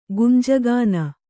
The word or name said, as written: ಗುಂಜಗಾನ